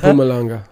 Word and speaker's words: Mpumalanga: Mpumalanga.